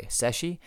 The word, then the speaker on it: Szechi: Daniel Shezi, sorry, I mispronounced that. Daniel Seshi, S-Z-E-C-H-I, Seshi, of course, has written some great stuff on the Jacobites.